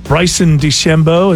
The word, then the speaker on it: Bryson DeChambeau: Bryson DeChambeau, is that how you say his name? – That's right.